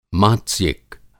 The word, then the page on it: मात्स्यिक: Hindi